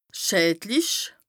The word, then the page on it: schädlich: Bas Rhin